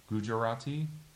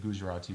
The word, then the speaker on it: Gujarati: As I said, it comes from two different versions of toddy, T-A-D-I, and tari. One is from the Marathi region, and one is from, I'm going to just butcher this, but Gujarati? – Yeah.